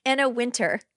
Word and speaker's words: Wintour: He's the one at Fashion Week sitting at next to Anna Wintour. – Wintour? – How'd you say her name? – Anna Wintour.